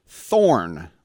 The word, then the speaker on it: Thorne: He is a registered dietitian, but also director of sports science for Thorne, with a silent E.